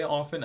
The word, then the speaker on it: often: But when I say often, I spell it O-F-F-E-N. I won't put the T in. Don't put the T in if you're not going to use it.